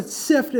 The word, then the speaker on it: Cyft: Yeah, LinkedIn, or go to SIFT, and it's spelled C-Y-F-T, and it's all linked together there.